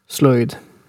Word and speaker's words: sloyd: slöjd in Swedish as well. – Mm-hmm. Yeah. Yeah, exactly. So for anybody who's unfamiliar with this term, it is spelled S-L-O-Y-D, slöjd, I guess you could say in English.